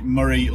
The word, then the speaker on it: Moray: The school I'm at, as every school in the Moray local authority, which is apparently what it's called. It's Moray. It's not Moray. It's Moray. It's spelt Moray, but it's Moray.